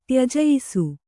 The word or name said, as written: ತ್ಯಜಯಿಸು